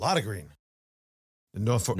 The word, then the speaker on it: Norfolk: And then my Norfolk Southern is pronounced Norfolk. Not Norfolk. – Norfolk. – I get a bunch of notes from people in the area. No, it's not pronounced that way. It's not Nor. It's Na.